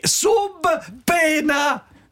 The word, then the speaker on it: subpoena: Subpoena, sì, è latino. È latino. – Sapina? – Sub pena! – Si chiama sapina, ma poi sì, lasci fuori una lettera. – Sì, sì, poena si legge pena.